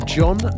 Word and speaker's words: Dgohn: That was followed by John, which is spelled D-G-O-H-N. So thankfully, they do actually alert you to that as how it's meant to be pronounced. Just John. J-O-N would have worked.